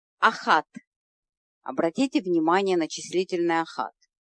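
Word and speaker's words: Ахат (אחת): Очень часто израильтяне, которые произносят миспар стами абсолютно правильно, делают ошибку в одном единственном числительном. Это числительное ахат. Вот все числительные в номерах телефонов они произносят в женском роде, а вместо ахат почему-то ошибочно говорят эхад. Не делайте эту ошибку. Но когда услышите ее, знайте, это ошибка.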